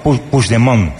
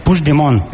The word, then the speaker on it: Puigdemont: No, Puigdemont. – Puigdemont. – Vale. – Puigdemont. – Eso es. – Sí, lo dices como francés, así. Puigdemont, parece como una isla.